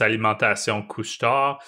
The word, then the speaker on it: Alimentation Couche-Tard: So it's Alimentation Couche-Tard, which released their fiscal year. – I love when you say the name of that company because I just butcher it. – Yeah, the D is silent.